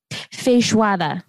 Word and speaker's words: feijoada: Feijoada.